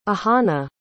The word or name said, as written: ahana